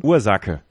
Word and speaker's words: Ursache: Aber wie wird er ausgesprochen? – Ursache. Weil das CH wird als K.